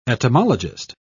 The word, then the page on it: etymologist: British English